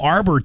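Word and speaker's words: Arbour: Me getting into the Nicole Amber deal... Arbor deal. I don't know how you say it. It's Nicole Arbor. Arbor.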